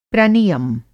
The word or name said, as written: प्रनियम